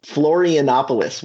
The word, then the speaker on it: Florianópolis: It's R-E-C-I-F-E. And we went as far south as Florianópolis. I think I mispronounced that. – Florianópolis, which is crazy because I've actually been there.